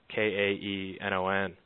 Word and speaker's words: Kaenon: The glasses I've been racing in are Keenan's. I believe they're based out of the UK, and not huge here. It's spelled. It looks like Canon. It's Kaenon. But they've got great lens technology, and just a real crisp view.